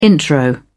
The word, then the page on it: intro: British English